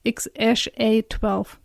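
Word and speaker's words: X Æ A-12: Also ausgesprochen wird es X-A-12.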